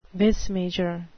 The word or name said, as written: vis major